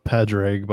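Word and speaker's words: Pádraig: It's a Padraig, by the way. There's a golfer. Anyway, it's a pretty. – I didn't want to butcher it a second time. – It's an Irish name.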